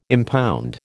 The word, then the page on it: impound: American English